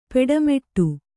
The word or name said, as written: ಪಟ್ಟೆಮಡಿ